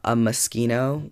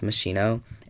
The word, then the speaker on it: Moschino: I bought a Juicy perfume, a Moschino, which if you're thinking you don't know what that is, it's a lot of people think it's pronounced Moschino. It's like that bear designer.